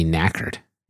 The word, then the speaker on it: knackered: What does it mean to be knackered? Do I have that one right? – Knackered. Am I saying that right? – Yes, yeah.